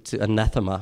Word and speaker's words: anathema: Anathema, you be cursed, to anathema. That's the right way to pronounce it.